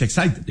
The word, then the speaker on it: Exide: Se escribe Exide, se pronuncia Exide, dentro decía la...